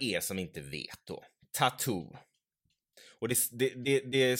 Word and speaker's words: t.A.T.u.: Eh, och namnet Tattoo alltså man uttalar det som man uttalar det engelska ordet tattoo som tatuering typ, men det är också en förkortad version av en rysk fras som jag inte ska uttala för jag kan inte ryska.